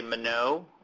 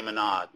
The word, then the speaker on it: Monod: And then perhaps the last most important point is about half the people say Monod with the silent D, because I think he was French, and the other people, half people say Monod, and to me both are right.